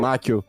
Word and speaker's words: Macchio: Não sei pronunciar o nome dele. Ralph Macchio. – Macchio.